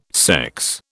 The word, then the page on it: sex: British English